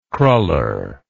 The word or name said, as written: kruller